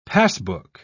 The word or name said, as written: passbook